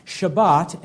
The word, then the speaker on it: Shabbat: And so to begin, once again, our English word Sabbath comes from the Hebrew word pronounced Shabbat, and it simply means a day of rest.